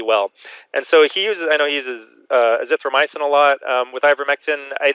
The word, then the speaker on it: Azithromycin: Azithromycin? – Yeah.